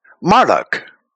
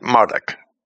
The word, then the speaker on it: Marduk: And in Babylon, it was used as a title for the god or idol, Marduk, or Marduk, but I would pronounce it Marduk.